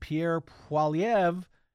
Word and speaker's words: Poilievre: And Pierre Poilievre, I said it right, and his Conservative Party, they were all set to absolutely clean the Liberal Party's clock.